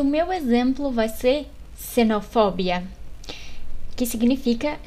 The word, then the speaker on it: xenofobia: Então, quando o X está no início da palavra, ele é pronunciado como se fosse uma C do português, xenofobia.